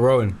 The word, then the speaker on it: Rowan: The finally, give us the correct pronunciation. – I think let's just stick with Rowan.